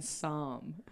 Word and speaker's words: Psalm: And Psalm. – Psalm? Like. – You like Tehillim. – With a silent P.